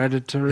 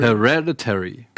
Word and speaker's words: Hereditary: Hereditary. – Hereditary.